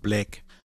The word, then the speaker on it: Black: Aber es war gut, dass du es nochmal buchstabiert hast, weil du hast es mit Ä ausgesprochen. Black. Mit dem Code Black.